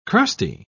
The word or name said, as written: crusty